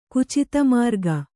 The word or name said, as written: ಕುಚಿತಮಾರ್ಗ